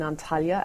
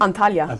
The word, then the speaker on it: Antalya: Antalya at their training base in Turkey.